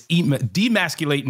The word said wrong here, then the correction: emasculating: men because, am I saying it right? Demasculating or emasculating? – Emasculating.